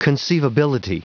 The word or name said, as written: conceivability